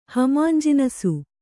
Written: ಹಮಾಂಜಿನಸು

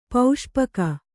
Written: ಪಾಸುಪೊಕ್ಕು

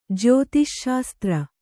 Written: ಜ್ಯೋತಿಶ್ಶಾಸ್ತ್ರ

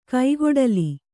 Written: ಕೈಗೊಡಲಿ

